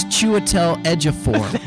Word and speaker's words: Chiwetel Ejiofor: Well, if Cheetawal Ejiofor wins. – What? A Cheetawal to die to for? To die for a proper pronunciation. It's Chiwetel Ejiofor.